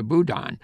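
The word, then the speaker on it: bodhrán: who will play an instrument called the boudin. It's spelled B-O-D-U-H-R-A-N, but the R is silent.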